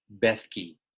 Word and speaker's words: Bethke: Bethke.